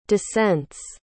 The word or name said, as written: descents